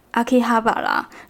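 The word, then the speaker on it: 秋葉原: 其实团名呢取自东京的秋叶原，秋叶原叫做。阿基哈巴啦，所以叫做 AKB 嘛。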